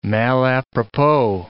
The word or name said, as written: malapropos